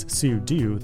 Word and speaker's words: sudo: And finally, the sudo command actually means super user do, and that makes sudo the proper way to say it.